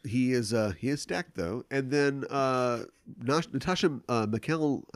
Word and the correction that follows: Natasha McElhone: I never know how to say her name. – Okay. – Although she's herself Irish, right? Isn't she? – I have no idea. – Natasha McElhone.